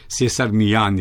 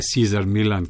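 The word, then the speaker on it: César Millán: Na vse zadnje je tudi ta slavni čarovnik s kuški, Cesar Millan je prav izgovor, ne Cesar Millan, kot rečejo Američani.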